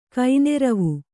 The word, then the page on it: ಕೈನೆರವು: kaineravu; kannada